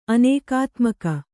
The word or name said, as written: ಅನೇಕಾತ್ಮಕ